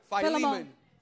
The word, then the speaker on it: Filemón: Filemón.